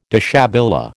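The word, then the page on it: déshabillé: American English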